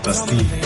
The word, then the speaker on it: Bastille: Yeah, we in our terrible English accents we say Bastille, even though it's clearly Bastille.